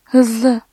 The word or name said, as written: hızlı